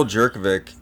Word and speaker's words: Jurkovic: Yeah, it's Phil Jurkovic, and he Jurkovic.